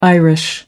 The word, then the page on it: irish: American English